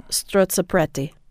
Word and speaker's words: strozzapreti: Any good Italian Catholic did I say it right? Yeah. Strozzapreti. I did apparently.